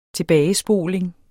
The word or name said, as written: tilbagespoling